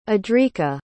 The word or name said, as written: adrika